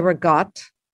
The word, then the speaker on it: ricotta: I would say ricotta, but I'm from LA. – Right. – You say rigot.